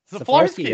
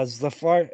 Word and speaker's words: Szaflarski: He's versus he's fighting Kevin Szaflarski. Is that right? Yeah, Szaflarski. Yeah.